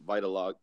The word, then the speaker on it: vitiligo: So to kind of really see the benefits for the vitiligo, how do you say it again? – Vitiligo.